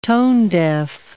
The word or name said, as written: tone-deaf